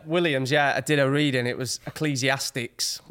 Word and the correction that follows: Ecclesiastes: What was Ecclesiastes. – It? – Ecclesiastes.